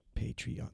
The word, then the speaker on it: Patreon: It's either two or three syllables, depending on how you pronounce it. – Right. – Patreon. How do you hit that with two or three syllables? – Patreon.